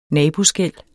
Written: naboskel

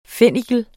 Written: fennikel

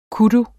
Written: kudu

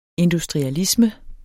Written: industrialisme

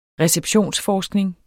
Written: receptionsforskning

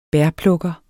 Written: bærplukker

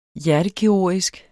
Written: hjertekirurgisk

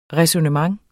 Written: ræsonnement

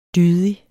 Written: dydig

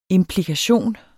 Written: IMPLIKATIONERNE

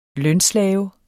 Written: lønslave

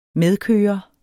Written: medkører

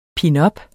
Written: pinups

